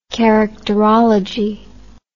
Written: characterology

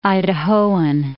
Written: Idahoan

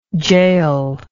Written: jahel

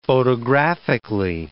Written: photographically

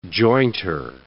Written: jointer

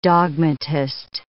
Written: dogmatist

